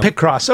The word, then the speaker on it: Picross: I just said Pick cross, but yeah, I don't know. – Pick cross. Okay, that, that makes sense because it's a picture.